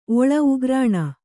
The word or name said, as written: ಒಳ್